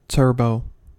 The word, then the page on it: turbo: American English